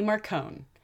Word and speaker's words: Marcone: Tommy Tom, bodyguard to gentleman Johnny Marcone. Rhymes with scone, not baloney.